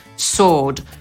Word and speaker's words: sword: Zaprzysiężony miecz, czyli sworn sword. Sworn pochodzi od czasownika swear, czyli albo przysięgać, albo przeklinać. No a sword to pewnego rodzaju wyjątek i dlatego tego W tutaj nie wymawiamy. Czy w angielskim jest dużo takich słów, gdzie W jest nieme?